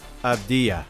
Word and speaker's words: Avdija: So it's pronounced like a Y. – Oh, Avdija. Right, not ha, but ya. – So it's a Y, so the J is a Y.